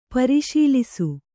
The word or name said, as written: ಪರಿಶೀಲಿಸು